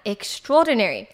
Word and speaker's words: extraordinary: 在哈利波特被这个霍格华兹录取之前呢，他以为自己仅只是一个平凡的小男孩。那跟他的反义词呢，则是 extraordinary，他前面加了一个 extra 的感觉。我很常听到很多人会把这个字念成 extraordinary，虽然说好啦，这个发音其实并不是很标准。不过这样真的很帮助你记这个单字。好，那今天呢，我们就来学学它正确的念法，叫做 extraordinary。extraordinary，那这边 trao 呢会发 troll 的音。那 extraordinary